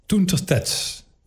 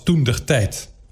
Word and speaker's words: toentertijd: Toentertijd. Toentertijd is de Loonse tegenhanger van toentertijd. Dat woord wordt in het Nederlands vaak uitgesproken als toendertijd, omdat het verward wordt met indertijd met een D.